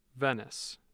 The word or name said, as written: Venice